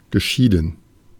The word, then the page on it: geschieden: /ɡəˈʃidən/; /ɡəˈʃidn̩/